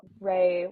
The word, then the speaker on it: Rae: My TikTok is dot it's Emma Ray. That's my name. Ray with that's spelled RAE. Period. It's Emma Ray, RAE.